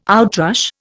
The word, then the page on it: outrush: Indian